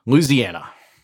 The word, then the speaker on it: Louisiana: How do you say it? – I say it like a native-born son of the South. Louisiana.